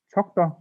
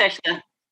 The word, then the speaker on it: Turkta: And this time I have the great pleasure to talk with two of the members from the trio Tokta or Turkta. Maybe you can uh, correct me how it's supposed to be pronounced. Pronounced Turkta. Turkta. Yeah.